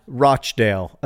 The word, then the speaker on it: Rochdale: Rosianna, how do you say Rochdale? Rochdale, apparently, but Britisher.